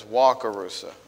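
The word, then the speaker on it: Wakarusa: I think I said Wackarusa, but I guess it's Wackarusa. I meant nothing by it. I just thought that's what it was, the pronunciation, but it's my little New York accent, I suppose, to some extent. So I want to get it right. So it's Wackarusa.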